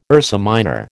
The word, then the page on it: Ursa Minor: American English